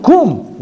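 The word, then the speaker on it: Kum (קום): Hainu, kum, get up.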